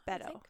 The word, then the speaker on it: Beto: Beto, yeah.